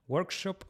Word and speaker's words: workshop: acuérdate que cuando hay una SH hay que arrastrar y es un workshop y no un workshop.